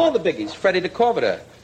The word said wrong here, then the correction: De Cordova: How do you say DeCorvita? Huh? You kept mispronouncing it all night at the party. De Cordova.